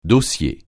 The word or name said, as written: dossier